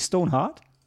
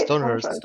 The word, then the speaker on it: Stonehurst: Stone, echt, echt Stonehurst? Ich habe ohne Scheiß, nicht Stoneheart? – Nee, Stonehurst.